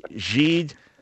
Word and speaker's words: Gide: G-I-D-E. Jeed, welcome to the program. Where are you from originally? – Thank you, Dr. Brown. Originally from Haiti. – Haiti, okay. Yeah, so French pronunciation.